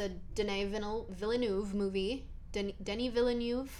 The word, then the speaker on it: Denis Villeneuve: Denis Villeneuve. – Sure. – I'm so sorry if I didn't say that right, but I think I did because I heard him say it himself, so that's right.